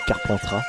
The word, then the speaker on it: Carpentras: Je sais plus si on prononce le S. Carpentras. Je crois qu'on ne prononce pas le S.